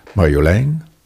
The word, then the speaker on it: Marjolein: Heeft ook de klemtoon op de laatste lettergreep. Gaat het naar boven. Marjolein. Niet zoals uh, ja, maar goed, in ieder geval, dit, en het, het, huppelt en het is, het is, het is leuk.